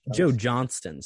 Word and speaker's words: Joe Johnston: Pretty Joe Johnston, sorry. – Yeah, said Johnson. His name wrong.